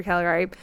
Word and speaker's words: Caligari: So if you look at the cabinet of Dr. Caligari, am I saying that right? Dr. Yep. Caligari.